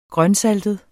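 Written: GRØNSALTET

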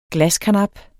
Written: glaskarnap

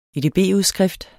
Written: edb-udskrift

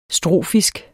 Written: strofisk

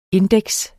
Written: INDEX

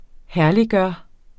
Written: herlig

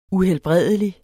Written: uhelbredelig

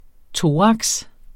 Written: thorax

